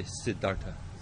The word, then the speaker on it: Siddhartha: Siddha? Artha. Say Siddha? – Siddha. – Artha. Now say Siddhartha. See how that goes? So you know, right? That this is a long A now. That'll become a long A because a short A and a short A have come together.